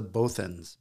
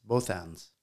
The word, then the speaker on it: Bothans: I believe they're pronounced the Bothans. Bothans.